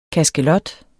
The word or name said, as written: kaskelot